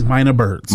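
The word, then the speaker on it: Mynah Birds: But it was I'm probably going to mispronounce this, but it said the Minin Birds or Mina Birds. – Mina Birds. – Mina Birds. – Yes.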